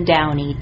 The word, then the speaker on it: Downey: You know me, Jim, Kirsten Downey, D-O-W-N-E-Y. – And I'm Alexis Simendinger, and that's spelled S-I-M-E-N-D-I-N-G-E-R, and I'm part of the writing team also here at the FCIC.